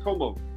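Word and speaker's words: Comeaux: My last name is pronounced Como. – Como. – EAUX. Yeah, EAUX in French is like oh, so it's just Como.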